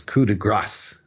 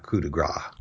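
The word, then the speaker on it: coup de grâce: If they don't know better, when there's an E following the consonant, then you do pronounce the consonant at the end of a French word. So it's coup de grace, not coup de grace.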